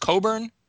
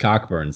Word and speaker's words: Cockburn: It's pronounced Coburn. – Yes, it is. And okay. I was lectured about this by Steve, as though I would think that two phonetically compatible letters in the middle of his name would just be silent for no reason. And it's also much more hilarious if his name was pronounced Cockburn.